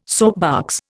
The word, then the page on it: soapbox: Indian